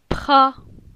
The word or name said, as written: Pra